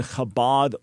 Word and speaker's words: Chabad: where you can go is the Chabad website.